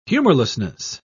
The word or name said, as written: humorlessness